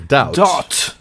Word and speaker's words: doubt: Да, би не читается в этом слове. – Doubt.